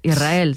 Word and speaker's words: Israel: Que suene la S, Israel, sí, es difícil pronunciar.